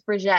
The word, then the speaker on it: Brigette: Friends will correct people; they'll be like, "No, it's Brigette."